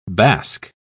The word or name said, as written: basque